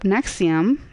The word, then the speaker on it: NXIVM: His group, Nexium, it's spelled N-X-I-V-M, presented itself as a female empowerment organization.